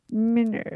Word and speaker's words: Minerve: Minerve. – At least that's how the French pronounce it.